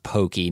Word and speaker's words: Pocky: But I remember being told at the time that it was pronounced pokey, not pocky. – Okay. – Which pokey is closer to the Japanese pronunciation.